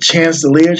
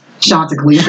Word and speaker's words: Chanticleer: Is it Chancellor? Chandelier? – Chanticleer. – Ooh, you got it so wrong, my nigga.